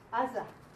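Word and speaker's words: Aza (עזה): The word Gaza starts with the Ayin. Mm-hmm. Gaza. You know where Gaza is, right? – Mm-hmm.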